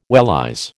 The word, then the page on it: wellies: American English